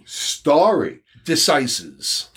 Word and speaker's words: stare decisis: It's spelled like stare, but it's pronounced stare decisis.